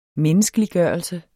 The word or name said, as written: menneskeliggørelse